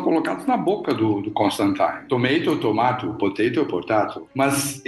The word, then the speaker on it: Constantine: O, o Constantine ele vira e fala assim: não, não é Constantine, é Constantine.